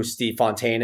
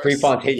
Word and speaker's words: Prefontaine: So if I said the name, you all know who Steve Fontaine is? – Yes. – Steve Prefontaine. Okay. – Of course. – Prefontaine.